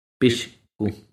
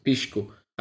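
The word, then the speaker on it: pisqu: Se dice de pisco. Pishku. Pishku. Ah, pisco. Que significa ave.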